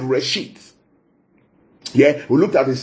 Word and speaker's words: reshiyth: Yeah, so in the beginning, that phrase in the beginning is the Hebrew word reshit. I'll spell it for you. Reshit. R-E-S-H-I-Y-T-H. Yeah, that's the word there, reshit. R-E-S-H-I-Y-T-H.